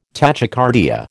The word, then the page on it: tachycardia: American English